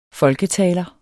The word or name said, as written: folketaler